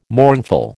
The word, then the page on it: mournful: American English